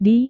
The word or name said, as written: di